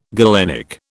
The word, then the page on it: galenic: British English